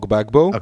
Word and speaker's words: Gbagbo: d'Ivoire, Laurent Gbagbo.